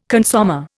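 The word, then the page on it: consommé: Indian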